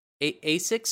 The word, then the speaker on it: ASICS: So there's an, how do you say this, ASICS? ASICS store? Is that how you say it? Yeah. Yeah.